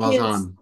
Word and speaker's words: Valjean: And I think people really like that love triangle, the Valjean, am I pronouncing it correct? – Yeah, Jean Valjean.